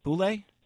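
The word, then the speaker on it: Bouley: Boulay? – Yes, that's correct.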